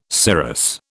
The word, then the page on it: cirrus: British English